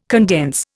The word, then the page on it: condense: Indian